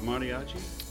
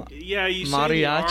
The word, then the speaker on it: mariachi: How do you say it? – You mariachi? Say you are a little bit like you a soft T. – Roll it a little. Yeah. Mariachi.